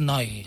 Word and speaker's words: Neu: de llavors dèiem Neu, el grup Neu, però jo no sé exactament com es pronuncia. – Tinc entès que en Alemanya es diu, és Noi.